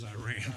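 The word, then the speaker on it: Irion: Yes, I must mispronounce that county, Your Honor. We've said Erie in the whole way. – It is Iran, but that's all right.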